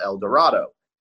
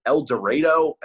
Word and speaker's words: El Dorado: It is a beer from Walnut River Brewing Company, which is – you know, okay, so like in Colorado, they had a place called El Dorado. There's a place in Kansas, it's the same thing, El Dorado, but they pronounce it El Dorado.